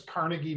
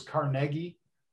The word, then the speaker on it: Carnegie: And, you know, other than perhaps Andrew Carnegie, I always pronounce it that way, Zach, because that's what I heard, even though everybody says Carnegie Mellon. I always heard it was Carnegie. – It probably was Carnegie in the Scottish.